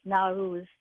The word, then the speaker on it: Nowruz: and it's spelled here as Nowruz, like Nowruz, and it's the first day of spring every year.